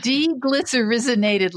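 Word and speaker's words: deglycyrrhizinated: I also wondered about something called DGL, diglycerin. Help me with the pronunciation. – Deglycerinated licorice, yeah.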